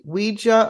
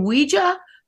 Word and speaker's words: Ouija: Phonetically, on the back of some boxes, originally, early 1900s, they actually told you how to pronounce it. They said Ouija. Definitely was a Ouija. And so that's how it was meant to be pronounced.